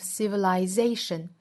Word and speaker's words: civilization: Every leap of 复原连读 leap of civilization. Civilization 这个词呢是以 t i o n 结尾的多音节单词，中音呢一般是落在倒数第二个音节 z 这个音节上。